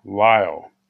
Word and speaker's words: Lisle: that's H-A-R-T-N-E-T-T, Dr. Russell Humphreys, whom I've already mentioned, and Dr. Jason Lyle, and his last name is pronounced L-I-S-L-E, not Y.